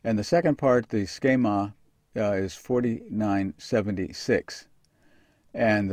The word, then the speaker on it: schema: And when we get there, we discover that it's schema. That's an S, that's a CH, that's an E, a long E, that's an M, and that's an A, schema.